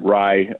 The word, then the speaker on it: Ray: It actually pronounces it Rye. – It's Rye. Thank you.